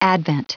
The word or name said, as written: advent